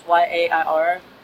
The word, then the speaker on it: YAIR: So the acronym is YAIR. And if you like know how to pronounce Spanish, we just started saying Yair. So not to say YAIR, but we had a DACA and DAPA workshop in June at the Adelante Center on Lorna Road.